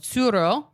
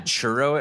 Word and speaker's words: Tsuro: You're going to have to spell it because I thought you said churro at first. And I was like, I love churros. Where are we getting one? – It's spelled T-S-U-R-O.